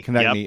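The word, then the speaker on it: Konecny: Got turned around on a, is it Konechny? I always say his name wrong. – Konechny.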